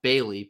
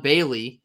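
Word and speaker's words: Baly: I believe his last name is pronounced Bailey, but it's B-A-L-Y. Mm-hmm. Javon Bailey.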